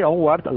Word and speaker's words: Onward: Pues no es Titanic, es Onward. ¿Así se pronuncia así o no? – Sí, Onward.